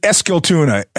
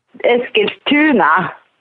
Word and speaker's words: Eskilstuna: I pronounced it Eskilstuna. Is that wrong? I put the emphasis in the wrong place. What is it? Say it again. – Yeah, Eskilstuna. And tuna is like town.